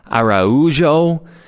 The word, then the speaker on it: Araujo: I thought um, I thought Thiago Almada for Atlanta, and I believe how you pronounce his name is Araujo for Atlanta.